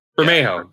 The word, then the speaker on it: Bermejo: And then when it finally, they're like, hey, look, here's the covers, you know, that Lee, how do you say his name? Barimo? – Okay. Yeah. – You know what I'm talking about? – Bermejo. – Yeah. There you go.